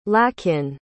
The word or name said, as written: Lakin